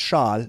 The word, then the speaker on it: Schaal: So, Kristen, number one, your last name is Schaal. – Yes.